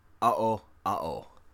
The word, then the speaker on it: Aoao: And it will be covering the Owl Owl, which is spelled A-O-A-O, and comes from Guarani region in South America.